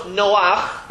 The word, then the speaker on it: Noach: And the reason is because if you look at his name, and we know it's Noach, that's how it's said, but look at the way it's spelled.